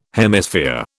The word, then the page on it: Hemisphere: British English